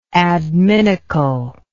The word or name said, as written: adminicle